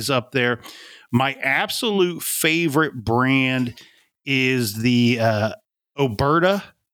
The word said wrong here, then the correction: Oberto: Oberto, I think is how you pronounce it.